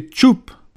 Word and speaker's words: coup: C'est ainsi que le mot « coup » C-O-U-P, était prononcé « tchoup ».